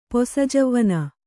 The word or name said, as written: ಪೊಸಜೌವನ